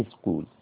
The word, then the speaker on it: school: a Quran school قرآن مفهومة، school اللي هي ch هنا تنطق k. خلاص كده. school أو c هنا بعد الاس الأولى دي تنطق k لوحدها، فاسمها school.